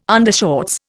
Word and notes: undershorts: Indian

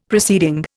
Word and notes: proceeding: Indian

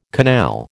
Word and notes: canal: American English

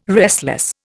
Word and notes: restless: Indian

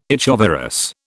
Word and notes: echovirus: British English

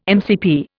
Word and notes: MCP: Indian

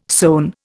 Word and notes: sewn: Indian

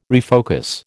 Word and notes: refocus: American English

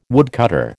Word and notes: woodcutter: American English

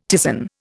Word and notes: ptisan: Indian

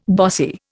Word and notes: bossy: Indian

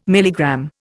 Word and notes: milligram: Indian

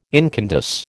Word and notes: incandesce: American English

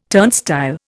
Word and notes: turnstile: Indian

